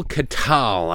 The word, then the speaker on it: Katyal: That was Neil Katyal, I believe it's pronounced K-A-T-Y-A-L.